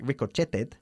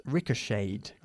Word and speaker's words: ricocheted: So we don't pronounce the T. The past, which looks like ricocheted, is pronounced ricocheted.